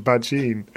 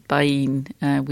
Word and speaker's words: Bajin: And I thought he'd said Bajin. Turns out he hadn't. So I've been corrected by a number of people. Thank you, Twitter. – It has its uses. Correcting us all on our pronunciations apparently is one of them. So, yeah, apologies, Sacha Baín.